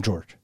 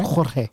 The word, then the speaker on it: Jorge: Pronounce his name George. – It's possible. – Is that a normal thing? – No. – Because that's not. It's Jorge, right? – It's Jorge. – Jorge.